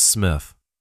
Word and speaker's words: Smith: Hello, my name is Lucas Smith. That's S-M-I-T-H.